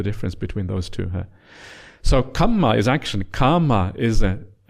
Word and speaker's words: kamma: Kama, double M, kama, long A. Yeah, kama, kama. And if you're used to Pali, you can hear the difference quite easily. Can you hear the difference? Western ears, it's hard to hear the difference. But if you have a kind of Sinhala background, you will hear the difference straight away, because kamma, double M, kama is a long A. It's different, pronounced quite differently.